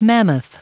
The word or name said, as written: mammoth